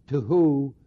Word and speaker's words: tohu: But the words are, the Hebrew words are tohu, vu, bahu. That's spelled T-O-H-U. Tohu, vu, bahu. B-O-H-U. They rhyme. Tohu, bahu.